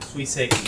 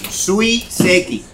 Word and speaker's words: suiseki: Suiseki. Suiseki.